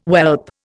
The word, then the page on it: whelp: Indian